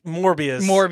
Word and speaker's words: Morbius: Was it going to be Morpheus? – You said the name wrong, but Morbius.